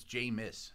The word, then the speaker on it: Jameis: That dude from Nebraska, his name's not James, by the way. I've made that. It's Jameis.